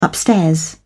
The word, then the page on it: upstairs: British English